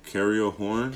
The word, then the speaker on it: Cariol Horne: Cariel Horn.